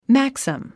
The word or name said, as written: maxim